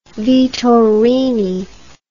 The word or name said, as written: vittorini